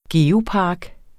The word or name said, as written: geopark